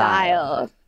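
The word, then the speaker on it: Loewentheil: Lowenthal rhymes with Nile. – Lowenthal. – Lowenthal.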